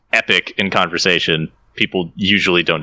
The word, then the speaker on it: Epoch: epic. – That's how it's pronounced. – Is it? – Yep. – I always pronounce it epoch because, like, when I epoch is actually a term used in like computer science.